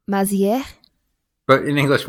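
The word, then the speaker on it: Mazières: Today on the show, I am so excited to have David Mazieres. Did I say that right? – No, it's French. It's Mazier, actually.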